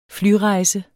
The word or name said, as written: flyrejse